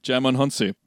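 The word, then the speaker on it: Djimon Hounsou: Jaimon Hounsou. Am I pronouncing that right? – Yeah, that's right. – Jaimon Hounsou.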